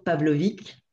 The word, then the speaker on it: Pavlovic: Mais en fait, c'est drôle parce que euh, quand j'étais plus jeune, euh, on disait toujours Pavlovic. Et puis